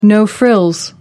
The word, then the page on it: no-frills: American English